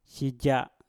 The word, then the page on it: sija: mpur